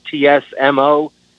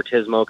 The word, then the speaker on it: TSMO: And TISMO, that term in and of itself is polarizing on the operation side, because there's a camp that favors saying it, you know, TSMO or TSM&O. I favor TISMO because it's two syllables and it's a lot easier to say.